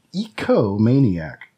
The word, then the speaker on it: ecomaniac: It is ecomaniac. Not egomaniac, but ecomaniac. That is spelled ecomaniac.